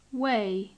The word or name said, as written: weigh